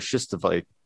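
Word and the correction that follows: stishovite: Stishavite. – I never pronounced that right.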